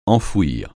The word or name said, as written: enfouir